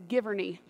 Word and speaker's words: Giverny: The name of the garden, I want to make sure I say it right, is Giverny.